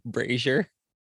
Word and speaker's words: brazier: Also, really quick, just for the posterity episode, it's pronounced brazier. – Oh, not brazier. – No, it's brazier, like Frasier.